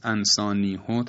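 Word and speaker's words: أنسانيهُ: که کلمه انسانیهو طبق قاعده باید انسانیه باشه، چون قبل از ها یا کشیده هست، صدای کشیده هست که در روایت حفص انسانیهو تلفظ میشه برخلاف قاعده.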